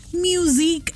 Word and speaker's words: MUSIQUE: म्यूजिक accreditation. मैं इसको इतना स्पेसिफिकली इसलिए बोल रही हूँ because the pronunciation is like that and the spelling is MUSIQUE which stands for म्यूजिक quality enhancement.